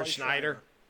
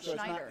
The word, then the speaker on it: Scheider: Yeah, Roy Scheider. Yeah. – There's actually no N in it. I checked it twice. It's not Schneider. It's Scheider.